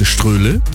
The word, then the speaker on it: Ströhle: Oder ich heiße Ströhle, wie das Stroh, nur niedlicher.